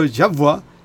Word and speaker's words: Yahvé: Y de hecho, en algunas Biblias, en vez de Jehová, se traduce Yahvé, porque la pronunciación está a medias. Jehová es en latín, pero Yahvá sería en hebreo.